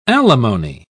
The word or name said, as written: alimony